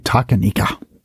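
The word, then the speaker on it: Talkeetna: So in 1997, an orange cat named Stubbs became an honorary mayor of the Alaskan town of, I can't pronounce it, so I'm about to butcher it, Takanikna. – Right, Takanika.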